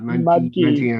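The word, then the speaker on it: Mudgee: Munchkey. – I love that. That is fantastic. I had never actually thought about it. Mudgy is how you say it.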